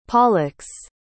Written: pollux

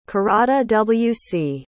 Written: caradawc

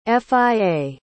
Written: fia

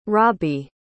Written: rabi

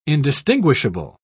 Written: indistinguishable

